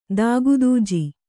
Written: ದಾಗದೂಜಿ

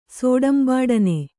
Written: ಸೋಡಂಬಾಡನೆ

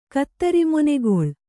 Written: ಕತ್ತರಿಮೊನೆಗೊಳ್

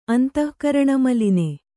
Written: ಅಂತಃಕರಣಮಲಿನೆ

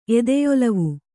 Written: ಎದೆಯೊಲವು

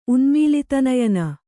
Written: ಉನ್ಮೀಲಿತನಯನೆ

